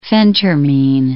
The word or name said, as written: phentermine